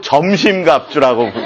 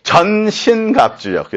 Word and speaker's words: 전신 갑주: 그 CD를 이렇게 들어보시면 전신 갑주를 자꾸 점심 갑주라고 그렇게 발음을 했더라고요.